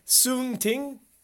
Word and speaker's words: Song Ting: Say his name again. – It starts with a T. Song Ting. – Song Ting.